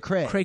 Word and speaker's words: cray cray: You got to stress the second one, cray cray. – Cray cray.